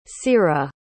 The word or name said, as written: ceara